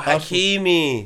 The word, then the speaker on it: Hakimi: Oh, Hakimi.